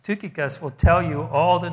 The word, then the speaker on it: Tychicus: It's pronounced Tukikos, this guy, not Tychicus or whatever. It's Tukikos.